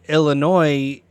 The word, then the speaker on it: Illinois: But fun fact, the state of Illinois, the S is silent.